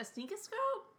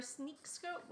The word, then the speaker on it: Sneakoscope: A sneakoscope? Or a sneakscope? Whatever. – I know! – Sneakoscope. Yeah, I think I said it right the first time.